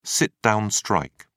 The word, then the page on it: sit down strike: British English